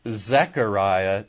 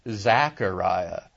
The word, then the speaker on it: Zechariah: And it is Zechariah, not Zachariah.